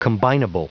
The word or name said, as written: combinable